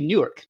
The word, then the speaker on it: Newark: And actually, depending on how you pronounce it, like if you're one of the locals, the biggest city with one syllable is actually Newark, New Jersey.